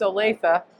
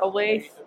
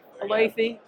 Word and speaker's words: Olathe: So I want to make sure people understand it's pronounced Olathe. Not Olathe. Olathe.